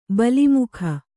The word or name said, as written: ಬಲಿಮುಖ